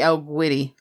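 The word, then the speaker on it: El Guiri: Is it Spanish? – I believe it's Spanish. – It's a Spanish studio. Yeah. – Then it would be El Guri.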